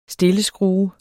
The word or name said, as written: stilleskrue